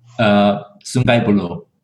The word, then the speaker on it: Sungai Buloh: but Sungai Buloh? – Sungai Buloh.